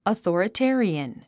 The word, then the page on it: authoritarian: əθɔ̀ːrəté ə riən; アソーラテェリアン